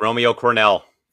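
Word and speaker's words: Romeo Crennel: Romeo Cornell is the interim head coach right now for the Houston Texans. If I got that name wrong, let me know. – Yep, that's correct. Romeo Cornell.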